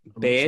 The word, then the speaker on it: Baird: Baird. – I'm not sure how that's pronounced. Baird. There you go. Baird. Neither of them. – It rhymes with Rory Laird.